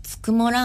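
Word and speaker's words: 九十九: 一番好きなのが恵比寿にあるつくもラーメン、 99 と書いてつくもって読むんですけど、つくもラーメンの豚骨ラーメンが大好きです。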